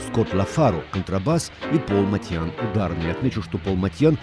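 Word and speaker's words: Motian: В первые годы 49 минут джаза я произносил его фамилию как Моушен. Потом великий знаток джаза, живущий нынче в Бостоне, Сабирджан Курмаев, написал мне, что Пол армянских кровей и что его фамилия и звучит по-армянски Матьян.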